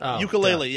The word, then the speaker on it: Yooka-Laylee: Yooka-Laylee, yeah.